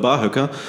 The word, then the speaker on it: Barhöcker: Barhöcker, Betonung liegt auf Barhöcker. – So wie beim Kamel.